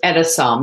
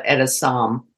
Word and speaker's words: Etessam: It's Etessam, like Edison, but Etessam.